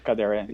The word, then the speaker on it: Kadare: and that's Ismail Kadare. Did I say his name right? – Kadere, yes.